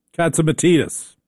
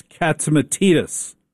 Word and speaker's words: Katsimatidis: Now, I work for a very unusual company in that it's owned by John and Margot Katsimatidis, pronounced Katsimatidis, as Margot taught me in no uncertain terms. It's Katsimatidis. She was very clear.